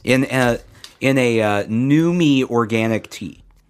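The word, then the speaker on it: Numi: It's new me. – New me. Organic tea. – Is that Newman's girlfriend? – That's how you say it. New me.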